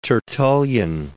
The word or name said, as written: Tertullian